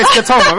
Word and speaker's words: scotoma: By the way, you've been saying it right this whole time. I just looked it up. So now you can say it with confidence. – Okay, scotoma, right.